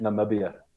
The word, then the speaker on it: Namibia: And it was really kind of fun because you're from Nambia. Pronounce it for me. Nambia? – Namibia. – Namibia. – Namibia, yeah.